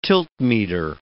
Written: tiltmeter

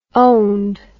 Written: owned

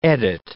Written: edit

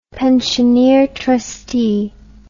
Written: pensioneer trustee

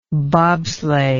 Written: bobsleigh

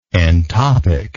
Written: entopic

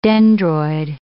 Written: dendroid

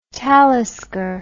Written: talisker

